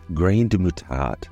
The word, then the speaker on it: graines de moutarde: In French, mustard seeds are pronounced grain de moutarde and spelled grain, G-R-A-I-N-E-S, de, moutarde, M-O-U-T-A-R-D-E.